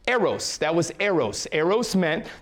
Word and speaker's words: eros: So the first one we have is eros, E-R-O-S, E-R-O-S, pronounced eros, right?